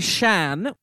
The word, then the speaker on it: Shan: Then she goes by Shan, pronounced like Sean the name, but I try to say like ah vowels so that we distinguish from Banshee to Americans, which like that's fine.